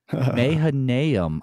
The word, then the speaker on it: Mahanaim: He blocks that whole strategy by putting Ishbosheth king over Israel at Mahanaim? I'm not sure how to pronounce that, actually. – Yeah, that sounds good. – Yeah, why not? Mahanaim, I'm told, actually, in my ears.